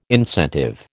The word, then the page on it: incentive: American English